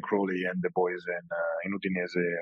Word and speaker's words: Udinese: I say Udinese, but I think you said it differently. I think I'm saying it wrong. How's the correct? – No, Udinese. That's correct.